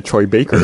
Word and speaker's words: Troy Baker: So, I thought you said Trey Baker that time too. I was like, are you gonna? – That was my way to say it correctly. – Yes. – To be like, I've always said Troy Baker.